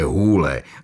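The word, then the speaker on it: Hole: Připravil se na to, že zpočátku bude v Austrálii opravovat lidem výslovnost svého příjmení a vysvětlovat jim, že se čte Hůle, aby si ho nepletli s The Hall, tedy dírou.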